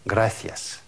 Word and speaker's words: gracias: Вот эта буква в классическом варианте произносится как «фе», «фе», «гратиас». Но большая часть, 90% людей, говорящих по-испански, произносят ее просто как «се».